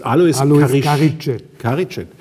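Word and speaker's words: Alois Carigiet: Alois Karicet.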